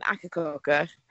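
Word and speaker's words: Akercocke: Oh, is there an extra syllable in there? I had no idea. – There's an E on the end of it, which I don't know. Everyone I know pronounces it as Echococker, so I feel like...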